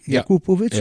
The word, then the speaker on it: Jakupovic: Wie Jakupovic? Ja. Ah ja, gut, dann habe ich das ja richtig ausgesprochen. Ja. – Naja, weil verrückt. – Es ging ja um eine Betonung dabei.